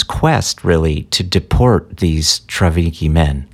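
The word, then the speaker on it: Trawniki: Yes, it's actually pronounced with a V, Travniki men, though it doesn't have a V.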